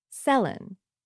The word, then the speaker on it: Céline: When she was born, Madame Dion pronounced her daughter's name Céline. Kids in school pronounced it Céline. Everyone around pronounced it Céline. But when she got popular in English Canada and the States, everyone pronounced her name Céline.